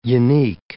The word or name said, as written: unique